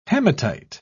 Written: hematite

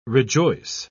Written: rejoice

